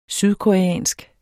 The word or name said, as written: sydkoreansk